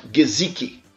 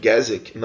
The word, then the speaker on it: Gesicki: Hoje a gente tem um protótipo aqui de um jogador ideal para a posição, que é o Mike Gesicki. Aprendi a pronúncia dele, eu achava que era Mike Gesicki. Não, Mike Gesicki. Tá, eu ouvi isso aí durante o draft. Pronúncia é essa, Gesicki.